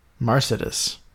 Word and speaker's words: marcidus: Probably a soft C there. Marcidus.